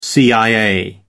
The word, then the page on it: cia: American English